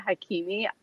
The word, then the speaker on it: Hakimi: It's actually Soheila Hakimi.